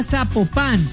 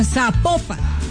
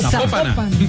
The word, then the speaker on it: Zapopan: Oiga, nos escriben los oyentes y que no se pronuncia Zapopan, sino que se pronuncia Zapopan. – Zapopan. – Es Zapopan.